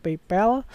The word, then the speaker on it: PayPal: Ada kayak PayPal, PayPal bukan PayPal ya, bacanya PayPal.